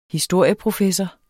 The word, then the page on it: historieprofessor: dansk